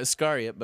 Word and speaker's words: Iscariot: The Smashing Pumpkins will continue their reissue campaign with the 1994 collection Pisces Iscariot. Am I saying that right? – Iscariot.